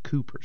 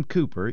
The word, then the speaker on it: Cowper: William, it's spelled Cowper, but I've heard through the years that it is pronounced Cooper, so that's the way I'll say it. William Cooper is one of God's gracious gifts to those suffering from depression.